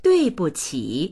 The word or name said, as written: dui4buqi3